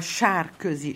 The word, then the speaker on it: Sarkozy: Ça se prononce en hongrois Sarkozy. Char, c'est la boue.